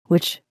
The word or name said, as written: witch